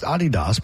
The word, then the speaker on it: Adidas: It's pronounced Adidas by the German manufacturer.